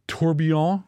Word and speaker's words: tourbillon: You're looking at an Apple Watch design with the square, the rounded corners, but they've got a tourbillon.